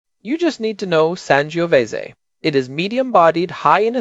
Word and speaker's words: Sangiovese: Fourth term, and this is a specific grape variety, Sangiovese, pronounced Sangiovese.